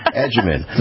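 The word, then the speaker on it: Agyeman: Edgeman. Phonetically figure that out like we would use in Italian, agita.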